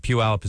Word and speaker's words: Puyallup: And Puyallup is a wonderful town.